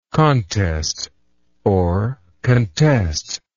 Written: contestingly

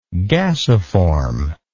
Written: gasiform

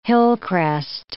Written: hillcrest

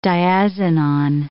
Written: diazinon

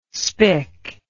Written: spic

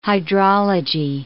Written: hydrology

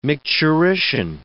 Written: micturition

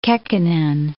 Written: Kekkonen

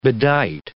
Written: bedight

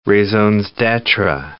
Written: raisons d'etre

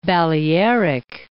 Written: Balearic Islands

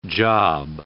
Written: job

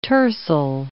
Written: tercel

